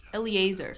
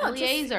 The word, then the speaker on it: Eleazar: Then how do you say this name? Elizar? – I think it's Eleazar.